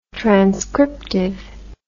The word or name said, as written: transcriptive